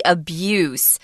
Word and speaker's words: abuse: But notice that the verb has a different pronunciation. You use the Z sound, abuse, and the noun is abuse.